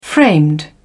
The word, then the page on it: framed: British English